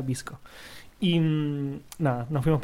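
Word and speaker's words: VSCO: Em, hay otra aplicación que es muy famosa que se llama BSCO, que se pronuncia Visco.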